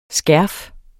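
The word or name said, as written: skærf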